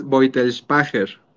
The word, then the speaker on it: Beutelspacher: Sí, es Boitelspacher. – Ok, Boitelspacher. ¿Es alemán? – O algo así. Es alemán, así es.